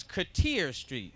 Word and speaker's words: Cartier Street: Now that they have remodeled that street and everything, they don't call it Cater Street no more. It's Cartier Street. No. Spelt the same, but pronunciation was changed.